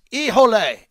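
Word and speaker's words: Híjole: Remember, when you celebrate a goal, yell, Iole! That's the tequila from Mexico. That is the official tequila of our show and the Henderson Silver Knights. Ijole. It's spelled H-I-J-O-L-E.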